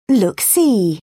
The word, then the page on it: look see: British English